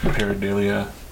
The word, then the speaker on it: pareidolia: So it's designed to look for that, and it creates its own pareidolia, or is that how you pronounce it? Pareidolia.